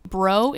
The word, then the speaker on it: Breault: Bro is spelled B-R-E-A-U-L-T. – Correct, yes. – Brealt. – But it's pronounced... – It is pronounced bro. – Like, what's up, bro?